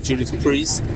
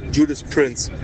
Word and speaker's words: Judas Priest: Abgesehen davon, dass ich ihn noch gar nicht kannte von Judas Priest. Super geil. Den Song muss ich mir mal anhören. Die Band heißt übrigens bei meinem Sohn Judas Prince. Ja, weil er die damals nicht richtig aussprechen konnte.